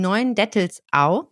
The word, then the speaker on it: Neuendettelsau: Sie ist geboren 1981 in Neuendettelsau.